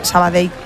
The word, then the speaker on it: Sabadell: A mí me lo decían mucho cuando estaba en Primera Federación porque yo también decía Sabadell, que es como se dice.